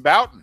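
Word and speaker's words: Bouton: Jim Boughton.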